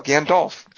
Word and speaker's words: Gandalf: Like, well, yeah, you want to pronounce the L, Gandalf, James Gandolfini, right.